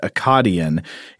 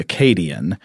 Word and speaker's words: Akkadian: You did an amazing job discussing the stories, and my only correction is that the second A in a I guess what I pronounced Akkadian is pronounced with the long A like hey, so that'd be Akkadian and I guess this, of course, refers to the ancient Mesopotamian culture, the, yes.